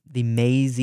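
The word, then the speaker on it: Maize: I always remember going to, in Milford Center, the Maze, the M-A-I-Z-E. I think it's still pronounced Maze, but that's how it's spelled.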